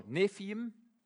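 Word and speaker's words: Nevi'im: De tweede staat N voor Nefiim. In het Hebreeuws schrijf je met een B, maar spreek je met een V uit.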